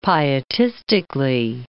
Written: pietistically

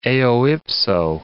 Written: eo ipso

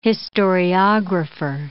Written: historiographer

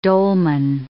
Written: dolmen

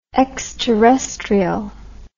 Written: exterrestrial

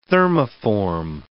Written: thermoform

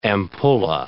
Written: ampulla

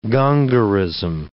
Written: Gongorism

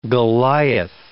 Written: Goliath

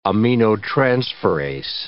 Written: aminotransferase

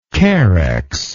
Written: carex

